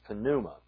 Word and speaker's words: pneuma: Now, we say pneuma in English, but in Greek it's pronounced pneuma.